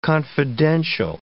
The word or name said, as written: confidential